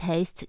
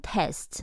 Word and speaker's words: taste: 可以放在 taste 的后方，it tastes good。吃起来蛮好吃的，那要小心哦。这里的发音 taste 长母音 a 不要读成 test，那是考试的 test。